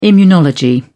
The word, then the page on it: immunology: British English